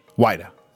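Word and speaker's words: whydah: And two new kinds of whita? – Yeah. – Whita. I'm saying that right? Right, correct.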